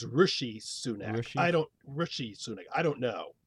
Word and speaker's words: Rishi Sunak: Now, I think it's Rishi Sunak. – Correct. – Is that right? Rishi Sunak.